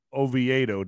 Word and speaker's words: Oviedo: And the Sudarium of Oviedo. Did I say that correctly? – That's correct. That's good.